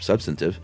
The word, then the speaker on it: substantive: Wade episode, Chuck at first pronounced substantive with the accent on the second syllable and instead of substantive, mm-hmm.